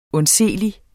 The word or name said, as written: undseelig